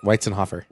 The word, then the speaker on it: Weitzenhoffer: I never know how to say it. – Perfect. Weitzenhoffer.